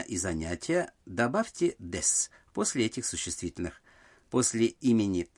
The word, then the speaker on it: です: Гласная «у» в слоге «су» связки «дес» не произносится, поэтому произносится просто «дес».